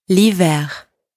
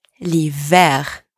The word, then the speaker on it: l'hiver: To finish, you do have to pronounce the R, le R, in the word l'hiver. L'hiver.